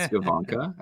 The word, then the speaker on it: Gawanke: Yeah, and we got the pronunciation on point this time. It's Gavanka.